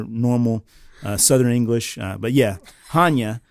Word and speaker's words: Chania: It is pronounced Chania. It's Chania.